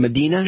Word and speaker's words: Medina: Debbie Medina, is that my pronouncing her correct? Medina? – Yes, sir.